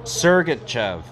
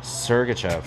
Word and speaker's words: Sergachev: Looking here. Sergachev. Okay. See, I couldn't see really that letter, the R. I could not see the R. Sergachev.